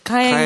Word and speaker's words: 花園: おつせ、かえん。 – かえんさん。 – はい。 – あ、かえんって、花園と書いてかえん。